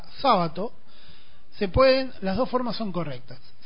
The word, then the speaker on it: Sábato: Pero en Italia se pronuncia, se escribe así, pero se pronuncia sábado, como esdrújula.